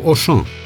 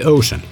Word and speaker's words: Auchan: La fel stau lucrurile cu Auchan, tot o companie franceză cu cartierul general la Croix. Pronunția predilectă și incorrectă la noi este Ocean, de parcă ar fi vorba fie de Billy Ocean, fie de franciza filmelor cu George Clooney, Brad Pitt și Matt Damon.